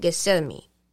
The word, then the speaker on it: Gethsemane: Gethsemane.